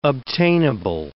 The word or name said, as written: obtainable